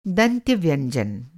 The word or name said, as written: दंत्य